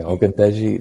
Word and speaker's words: Ogundeji: Ogundeji, have I said it right? – Pretty good. It's like 98% there. – So. Okay, Ogundeji, like, I thought he was a standout against the Jaguars against the run.